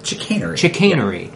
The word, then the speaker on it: chicanery: Chicanery.